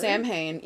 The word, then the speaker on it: Samhain: So it's like a Celtic religious festival, but it's actually supposed to be pronounced Samhain because it's a Gaelic word, but mm-hmm. most people say Samhain. So I'm going to just say Samhain.